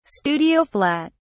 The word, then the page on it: studio flat: British English